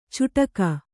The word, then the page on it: ಚೂಡಕ: kannada; cūḍaka